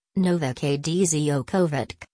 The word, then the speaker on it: Novak Djokovic: Novak Djokovic, Serbian, Novak Djokovic, pronounced Novak Djokovic, listen, born May 22, 1987.